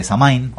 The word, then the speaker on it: Samhain: Entonces no es casualidad que la celebración, eh, que la celebración pagana celta de Samain o Samhain como eh, se pronuncia originalmente en gaélico,